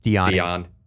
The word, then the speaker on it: Dionne: That's what I always call her, but it's spelled D-I-O-N-N-E. – I think it's Dionne.